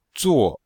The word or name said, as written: zuo4